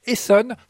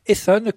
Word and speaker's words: Heson: On reste dans la ronde pour fêter la Bretagne avec cette fois-ci un rond de Saint-Vincent interprété par le talentueux quintette Esson, cela s'écrit H-E-S-O-N, Esson, composé de deux saxophonistes, Ewen Couriot au sax soprano et de Ilian Couriot au sax baryton.